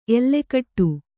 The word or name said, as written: ಎಲ್ಲೆಗೆಡು